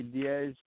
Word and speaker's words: Deas: I would say Deas, but it's pronounced many different ways. It's supposed to be spelled D-I-A-S, and then most people would say Diaz or Deas or like Buenos Dias.